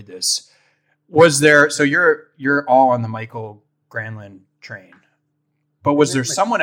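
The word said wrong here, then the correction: Mikael Granlund: Isn't it Mikhail Granlund? – Sure. Yes. Sorry.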